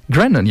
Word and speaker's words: Grennan: Grennan, zeg ik dat goed? Grennan, ja, Tom Grennan.